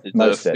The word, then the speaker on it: Mostert: Mostert, the old 49ers.